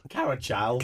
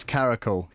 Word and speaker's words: caracal: Not even Carachal. An H in there. – I know, yeah. – No, it's Caracal. – Caracal, okay. – Yeah, that's how we pronounce it. I've been Googling it. – You're the first person to say Carachal, definitely. – Oh, I've been Googling and I saw the, I saw someone had written, there's a silent H in there, and I thought... – No, there's not.